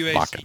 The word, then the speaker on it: Wacken: Yeah, because in German, the W is pronounced like a V. So yeah. It would be Wacken to you wacky Americans, but it's pronounced Wacken. – W-A-C-K-E-N.